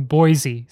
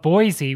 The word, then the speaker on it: Boise: And somebody in the third row of the Kennedy Center yells down at him, correcting his pronunciation of Boise with Boise. And it was like so passionate, so rabid about pronouncing it just the right way. – Yeah, we've already gotten corrected a couple of times. Because, yeah, everybody says Boise. Yeah. But there's no Z in Boise.